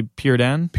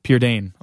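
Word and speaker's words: Prydain: I've always said Pride and Chronic. You say Pyrrha Dane. Pyrrha Dane.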